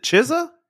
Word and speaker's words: Chizza: But there's no T in there. There's no like CK. It's C-H-I-Z-Z-A.